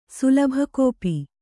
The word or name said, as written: ಸುಲಭಕೋಪಿ